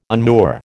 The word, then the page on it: unmoor: American English